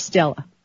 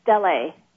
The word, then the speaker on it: Stella: You know, you sent me, am I pronouncing it right, Stell or Stella? – Stella.